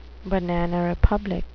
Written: banana republic

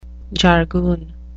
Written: jargoon

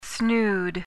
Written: snood

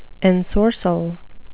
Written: ensorcell

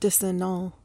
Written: disannul

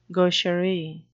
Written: gaucherie

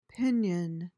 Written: pinion

